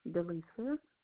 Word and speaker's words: Delisa: Delisa. – Can you spell that for me? – D-E-L-I-S-A.